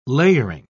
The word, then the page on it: layering: British English